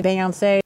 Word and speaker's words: Beyoncé: I'm sorry, who? – Beyoncé. – How do you, in the year 2024, not know how to pronounce Beyoncé? The only reasonable explanation I can come up with for why Susan Glasser would pronounce Beyoncé that way is because maybe she's trying to one-up all of us, including Beyoncé herself, by using the French pronunciation of her name.